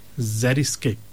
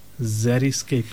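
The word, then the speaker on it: xeriscape: The word is zeriscape. The word is zeriscape. The spelling is actually unique because it starts off with an X. It's spelled with an X. X-E-R-I, and then the word scape, S-C-A-P-E.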